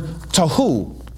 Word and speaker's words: tohu: You pronounce the word tohu. Tohu. Everybody say tohu.